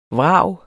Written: vrag

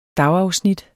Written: dagafsnit

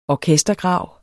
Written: orkestergrav

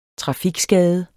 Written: trafikskade

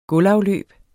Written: gulvafløb

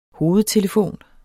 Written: hovedtelefon

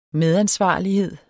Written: medansvarlighed

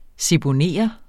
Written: seponere

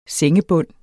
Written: SENGEBUND

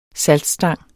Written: saltstang

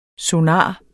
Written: sonar

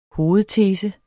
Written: hovedtese

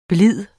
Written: blide

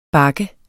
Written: bak

